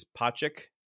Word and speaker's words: Pocic: And that would be Ethan, I think you say his name is Pocic, P-O-C-I-C.